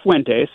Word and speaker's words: Fuentes: Now, do I say his name right? Is it Fuentes? Is that the last name? Am I pronouncing? – Yeah, it's Fuentes.